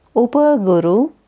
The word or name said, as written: Upa Guru